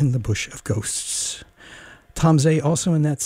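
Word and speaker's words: Tom Zé: Is the best I can do with Portuguese, but Tom Zay is correctly pronounced, and you need to listen to Tom Zay as much as you can.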